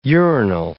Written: urinal

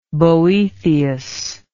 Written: Boethian

